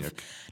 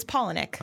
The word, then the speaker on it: Palahniuk: It's Palahniuk. Oh, like, you. Paula and Nick. Two first names as a last name.